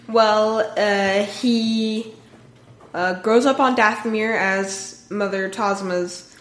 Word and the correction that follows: Talzin: Talzin. – I can never get her name right.